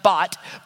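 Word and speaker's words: bat (בת): It's pronounced bat, but it's spelled bath. If we were to pronounce it in English, it would be spelled bath, but in Hebrew, it would be pronounced bat.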